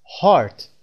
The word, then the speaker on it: heart: کلمه بعدی heart هست. heart، ببینیم صدای ای ای وجود داره، اینو باید چطور تلفظ کرد؟ مراجعه میکنید به فونتیک. اگر صدای آ و دو نقطه داشتید این رو میخونید heart، صدای آر، آر، heart.